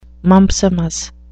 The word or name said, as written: mumpsimus